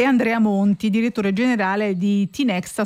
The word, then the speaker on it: Tinexta: Perché ero indecisa se pronunciarlo all'inglese Tinexta oppure Tinexta. – No, è Tinexta, va bene.